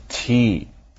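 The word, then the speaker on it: té: Okay then, what about all the other languages that use words that look and sound like the word tea?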